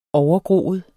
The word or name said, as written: overgroet